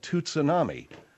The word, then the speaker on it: tsunami: Yeah, but there's a T in it. – It doesn't matter, you moron. You don't pronounce the T. It's tsunami. Tsunami. – Yeah. – Tsunami. Learn it.